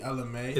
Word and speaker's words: Ella Mai: Is it Ella May or Mai? – It's definitely Ella May.